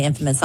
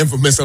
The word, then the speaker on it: infamous: Oh, I guess I'm putting the infamous on a different emphasis. – It's Amanda. – The infamous. You're putting the infamous on the wrong syllable.